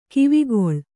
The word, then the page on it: ಗವಿಗೊಳ್: kannada